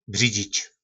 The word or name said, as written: bridzic